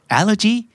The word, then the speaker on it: allergy: allergy กับ allergic นะครับออกเสียงไม่เหมือนกันนะครับ allergy allergic Do you have any allergies?